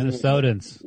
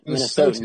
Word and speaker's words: Minnesotans: What what do they call Minnes- people who live Minnesotans? In, yeah, Mi- Minnesotans. You added an I in there for some reason.